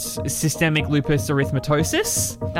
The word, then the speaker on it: systemic lupus erythematosus: She then was diagnosed with functional neurological disorder and systemic lupus erythematosus. – That's, wow, well done. That's a great pronunciation.